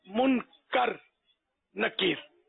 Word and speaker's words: منکر: جزاک اللہ خیر۔ منکر کے معنی ہے کہ انکار کیا گیا، اور منکر ہوتا ہے کہ انکار کرنے والا، منکر مانے انکار کرنے والا، اور منکر مانے جس کا انکار کیا جائے، انکار کیا گیا، تو یہ لفظ صحیح تلفظ منکر نکیر،